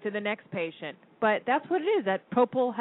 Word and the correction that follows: Propofol: Propofol. – Propofol.